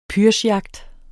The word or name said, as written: pyrschjagt